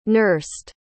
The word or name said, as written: nursed